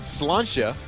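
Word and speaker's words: Sláinte: and somebody said slantia, and apparently that is what, if you've ever seen S-L-A-I-N-T-E, you know, I thought it was like slante or slainte.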